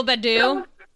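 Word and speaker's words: Akil Baddoo: Yes, Akil Badu. I'm pronouncing that correctly. – Yeah, Akil Badu.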